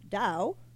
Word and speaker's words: Tao: Okay, comedian, writer, producer, comedy teacher, the founder of the Tao of Comedy, or property pronounced Dao in Chinese, meaning the path or the way, Bobby Oliver!